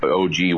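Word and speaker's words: ogee: And the overtopping level for the what's called the OG weir, and that's O-H-G-E-E, OG weir is the way it's pronounced.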